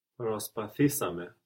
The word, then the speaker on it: προσπαθήσαμε: Προσπαθήσαμε. – Προσπαθήσαμε. Good. We're adding two syllables there with ame, so the accent also moves forward one from προσπάθησα. Προσπάθησα. Προσπαθήσαμε.